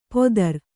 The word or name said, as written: ಪೊದರ್